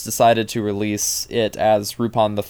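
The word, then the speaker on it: Rupan: which Rupin is just the Romaji pronunciation of Lupin. It's R-U-P-A-N. It's how they pronounce Lupin in Japanese, but they typically in the U.S.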